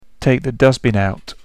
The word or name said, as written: take the dustbin out